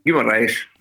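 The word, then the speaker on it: Guimarães: Jag vill inte ens försöka uttala det på portugisiska. – Kan nog göra det. – Guimaraes. – Ja, den är fin.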